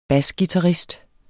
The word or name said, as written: basguitarist